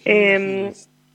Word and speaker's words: Hilst: Yo le pronuncio así. No sé, no he escuchado muchas otras maneras de pronunciarla. Me gustaba pronunciarla así. – Muy bien, Hilst.